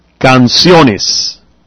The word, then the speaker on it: canciones: Still the stress on the O. Canciones.